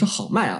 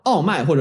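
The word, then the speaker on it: Homais: 啊，另外一位呢是好麦啊，这个 o m a 同样这个名字，呃，法语里面是这个 H，就是 H 不发音啊，所以说是 o m a 在呃，所以有些地方译为奥麦或者就是其他的欧麦什么的更准确一些。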